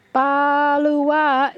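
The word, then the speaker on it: Baluwaa: Balawa is respect.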